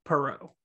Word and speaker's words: Perreault: It's spelled like parrot, but it's not pronounced the same way. It's Perrault, the French.